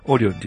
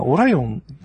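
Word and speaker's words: Orion: まあ、一応、うちは、他のメディアさんと横並びで、オリオンって言ってますけど、まあ実際は、まあでも、カタカナで書く以上、どっちも正確な発音じゃないんだけど。 – そうですよね。 – まあどうも、でも日本は基本的にあれですよね、星座とかの関係で、英語発音はあんまり採用されないんですよね。 – うん。 – ああ。 – うん。まあ、オライオンつってみんなオライオン通じるのはピスリチャーリーぐらいですからね。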